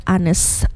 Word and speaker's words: honest: because it's silent H, right? Mm, yeah. – In the word honest. Okay.